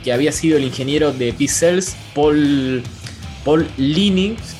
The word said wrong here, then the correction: Paul Lani: por ahí lo estoy pronunciando mal. Eh, sí, no, perdón, Paul Lani.